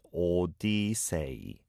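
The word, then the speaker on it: Odyssey (오디세이): That was Ha Hyun Sang with Odyssey, and that's how we say Odyssey in Korean, Odyssey. Okay, it's four syllables there.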